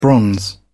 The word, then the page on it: bronze: British English